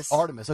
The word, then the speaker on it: Artemis: Artemis.